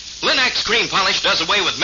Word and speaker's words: Linx: Remember to ask for them by name, Linex. It's spelled L-I-N-X. Linex.